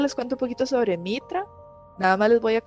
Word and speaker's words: Mitra: Mitra, no sé si lo pronuncia bien. Sí, Mitra.